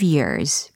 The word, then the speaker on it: year: 특히 과거 시제로 바꿔야 되니까 they got reunited 5년 후에 after 5 years. 그렇죠. 예전에 알려드렸던 발음 기억나십니까? 어, 년도를 이야기할 때는 year 이렇게 발음하고 귀를 이야기할 땐 ear 이렇게 발음한다라는 것도 점검하시면 좋을 것 같아요.